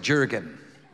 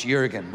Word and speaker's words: Jurgen: But my name is Jurgen with a J, so that's why I like Psalms, because when you look at my name, the way it's spelled, it looks like Jurgen, but it's not, it's Jurgen, which is really messed up when I go to Starbucks, because they're like, what's your name?